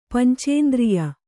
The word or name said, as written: ಪಂಚೇಂದ್ರಿಯ